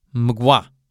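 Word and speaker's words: Mgła: Och med tanke på att Groza även är en Megua, Mgwa, Mogwa, L med ett streck igenom på polska uttalas w. Så det blir mgwa. Mgwa.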